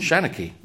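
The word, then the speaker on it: seanchaí: Shanachie? – Shanachie. Yeah, you did it. Perfect. – Now that I've heard you say it. – Yeah. – Yeah. – And the highfalutin way of describing what a shanachie is a cultural historian of a place.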